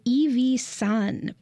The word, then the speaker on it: IVI-III: and it's by Evie Sun, but it's spelled IVI-III, and I means three, which is sun in Japanese.